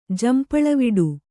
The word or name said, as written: ಜಂಪಳವಿಡು